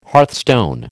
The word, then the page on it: hearthstone: American English